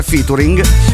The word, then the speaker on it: featuring: E a proposito di Featuring, vorrei dirvi che io so benissimo che si pronuncia Featuring,